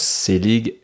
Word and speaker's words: Selig: Donc, Christian, comme ça se prononce, Selig, S-E-L-I-G.